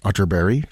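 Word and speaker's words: Atterberg: The Swedish composer Kurt Otterberg, spelled Otterberg but pronounced Otterberg, just in case you were Googling.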